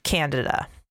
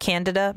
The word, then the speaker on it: Candida: I have looked into this because I was never taught anything other than Candida. Every professor I had, all of my colleagues say Candida. I've never heard anything other than Candida in my professional life. But I looked into it. Mm-hmm. Apparently, that is the British pronunciation, is what I have read.